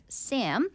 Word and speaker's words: SAHM: Stay-at-home moms, right, or you abbreviate that to S-A-H-M, or you actually pronounce it as Sam.